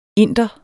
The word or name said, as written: Inderen